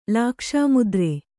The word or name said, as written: ಲಾಕ್ಷಾಮುದ್ರೆ